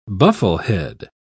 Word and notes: bufflehead: British English

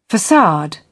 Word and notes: facade: British English

